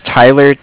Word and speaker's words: Tyler: My name is Tyler, T-Y-L-E-R. Mm-hmm. And I'm calling with Cardiff. It's spelled C-A-R-D-I-F-F as in Frank Frank.